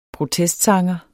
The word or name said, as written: protestsanger